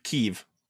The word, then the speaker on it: Keeve: Oh no, it rhymes with Sheev. Keeve.